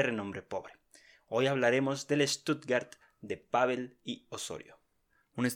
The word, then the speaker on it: Stuttgart: Sí, y la pronunciación buena de Stuttgart es Stuttgart.